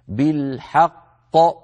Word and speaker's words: بالحق: لما نقول مشدد أيها الإخوة معناها حرفان، ساكن فمتحرك، لكن المتحرك الثاني باعتبار أنه متطرف في هذه الكلمة نقف عليه بالسكون، فيصير النطق بقافين، الأولى مدغمة في الثانية وهي غير مقلقلة، والثانية ساكنة مقلقلة، هكذا بالحق، حق هذه القاف الأولى، ق هذه القاف الثانية، من المقلقل منهما؟ الثانية، الأولى مدغمة فيها، لا بد من بيانهما.